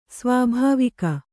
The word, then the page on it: ಸ್ವಾಭಾವಿಕ: svābhāvika; kannada